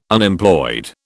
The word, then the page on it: Unemployed: British English